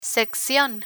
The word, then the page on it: seccion: spanish